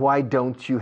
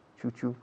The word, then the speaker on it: why don't you: Es curioso con la T de don't. Y con la Y de you, cuando se juntan, la pronunciación suena a chu, como chufla, chufla. Why aren't you? Chu-chu.